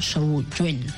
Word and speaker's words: Samhain: Una celebración parecida a la conocida como Al Samhain, pronunciado Suheim, bueno, eso, como se pronunciaría, ¿no? Sowen.